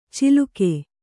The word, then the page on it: ಚಿಲುಕೆ: ciluke